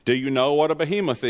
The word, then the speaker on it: Behemoth: According to Strong's Concordance, the audio pronunciation was Behemoth. Is that a stress on the last syllable?